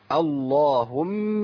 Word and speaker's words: اللهم: اللهم مالك الملك. اللهم، احنا قلنا لفظ الجلاله كلمه الله وكلمه ايه؟ اللهم. اللام هنا فخمت لان بدأ بايه؟ بدأ بهمزه، لان انا هبدا شايفين راس الصاد اللي موجوده على الالف اللي هو في قبل اللام لفظ الجلاله، قبل اللام اللهم.